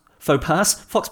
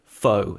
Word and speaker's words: faux: So faux pass, fox pass. I'm mixing them up now. So yeah, there was one guy who said, not me, but my 33-year-old buddy... Faux. He pronounces it fox and not faux.